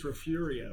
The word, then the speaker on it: Refugio: Refugio. – Actually, it's Refurio.